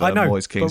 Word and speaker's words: Moise Kean: It's Moise Kean. It's very easy. Moise and Kean. David Moise, Roy Kean.